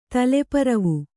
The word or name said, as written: ತಲೆಪರವು